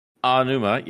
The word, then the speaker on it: Aonuma: Aonuma, yes, thank you.